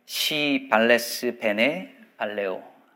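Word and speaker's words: Si vales bene valeo: 음, 라틴어로 발음할 때는 조심해야 합니다. 이렇게 읽거든요. 시 발레스 베네 발레오. 당신이 잘 있으면 나도 잘 있습니다.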